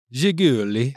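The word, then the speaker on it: Zhiguli: With the success the VAZ 2101 was seen in foreign markets, the original name Jiguli had to be changed because it was too hard to pronounce for non-Russian speakers. – I think you nailed it, though. Yeah. – Jiguli.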